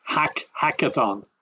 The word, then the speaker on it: hackathon: And I want to go back to a term you used, the agathon. Tell listeners what that is. Explain that a little more. – Okay, I mispronounced. It's hackathon.